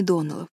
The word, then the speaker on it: Доннелл: «Простите», — запинаясь, произнесла Энн, пытаясь вспомнить, не было ли какого инцидента, связанного с детьми Доннеллов. «Кларис Алмира сказала, что вы произносите нашу фамилию, делая ударение на первом слоге. Однако, мисс Ширли, правильнее делать ударение на последнем — Доннелл.